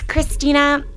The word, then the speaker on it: Kristina: It's Christina, K-R-I-S-T-I-N-A. And my last name is Libby, L-I-B, as in boy, B-Y, .com.